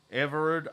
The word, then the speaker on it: Everard: And Mr. Ike Everard, is that how you say his name? It's E-V-E-R-A-R-D. – Yeah.